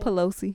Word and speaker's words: Pelosi: Pelosi.